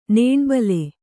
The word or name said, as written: ನೊಣಬುಲಿ